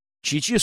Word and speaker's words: Чичи: Чичи с ударением на второй слог.